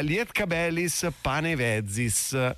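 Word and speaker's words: Lietkabelis Panevėžys: che io faccio molta fatica a pronunciare, ma adesso ci proverò. Vuoi farlo tu? Lietkabelis Panevezis, mi sembra giusto, forse l'accento giusto, mi fanno cenno di sì.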